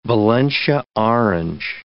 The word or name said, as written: Valencia orange